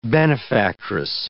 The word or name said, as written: benefactress